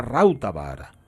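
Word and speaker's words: Rautavaara: Y esto es lo que ocurre con la música de Rautavara. – Que me imagino que al ser fines no se, no se dice Rauta sino que se diría Rautavara. porque son todas estrújulas.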